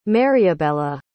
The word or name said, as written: mariabella